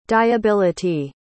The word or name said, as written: dyeability